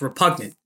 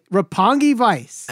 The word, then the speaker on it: Roppongi Vice: It's repugnant. Repugnant Vice. Repugnant. No, no, no. – It's Roppongi Vice. Say it right. Roppongi Vice.